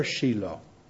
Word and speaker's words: Shiloh: Si dice Shiloh o Shiloh in italiano? Shiloh, ok. Mi piace più la pronuncia in inglese.